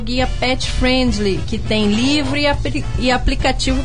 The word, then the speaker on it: friendly: E Socorro também são destinos pet friendly. Como é que fala isso, José? Friendly. – É isso aí mesmo.